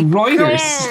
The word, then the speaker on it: Reuters: Oh no, it's Reuters.